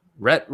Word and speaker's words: Rhett: I can't pronounce this. Reese, and Paul Wernick, who wrote Deadpool. – That would be Rhett. – Rhett Reese.